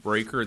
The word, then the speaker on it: Breaker: My name is Martin Breaker. That's spelled B-R-E-A-K-E-R.